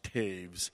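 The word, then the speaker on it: Toews: but although my last name looks like it's spelled Toes, it's actually pronounced Taves. It's, yeah, just like the hockey player, yeah. So it's a good German Mennonite name, and so that's the pronunciation for it.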